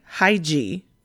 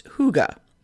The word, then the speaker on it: Hygge: It looks like Hygge, but it's pronounced Hygge.